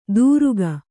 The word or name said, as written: ತುರುಕ